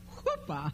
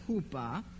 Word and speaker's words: chuppah: And then the couple would go into a private chamber, which is called the chuppah, which I may be pronouncing wrong, but that's the way I pronounce it. It is H-U-P-P-A-H. How would you pronounce that? Chuppah.